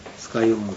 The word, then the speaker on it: 酸ヶ湯: はい、ということで、問 1の 9 番、酸ヶ湯温泉ですね。はい、酸という字を書きますけども、発音は酸ヶ湯温泉になります。はい、で、場所が地図上の中から選ぶんですけども、これが、青森県ですから2 番ですね。はい、大体この辺りですね。はい、酸ヶ湯温泉。